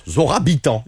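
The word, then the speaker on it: Zohra Bitan: En fait, ça se prononce Zora Bitan.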